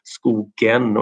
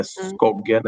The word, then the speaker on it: Skogen: Och som sagt, många har svårt med uttalet att det är skogen och inte skogen eller skoggen eller sådär.